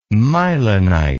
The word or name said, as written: mylonite